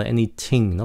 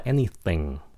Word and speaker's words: anything: Các bạn đừng có phát âm nó là anything, không phải là anything, nó là anything. Anything. Nhấn cái âm đầu.